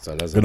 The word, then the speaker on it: Salazar: Salazar, oder ich weiß, wie der ausgesprochen wird. – Ja, genau so. – Salazar.